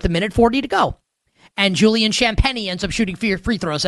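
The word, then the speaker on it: Champagnie: That should be how you pronounce his name, but it's Champagny.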